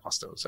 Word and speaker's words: Ostos: So Cynthia Ostos.